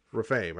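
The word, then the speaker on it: Rephaim: Is it Nephilim and Raphaim, I think it is. – Raphaim, okay.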